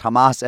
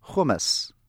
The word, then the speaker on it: Hamas: And while speaking of the finest minds of our time, presidential contender Ben Carson, in a speech last week, pronounced Hamas as hummus.